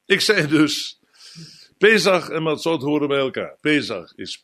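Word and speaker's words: Matzot (מצות): Matzot, dat wordt door de Oost-Europese Joden uitgesproken als Matzes. Die zeggen ook niet Shabbat, maar die zeggen Shabbos. Dus Matzes kent u allemaal, dat woord. Maar Matzot is de naam zoals de Sephardische Joden het uitspreken en zoals het vandaag ook in Israël gezegd wordt.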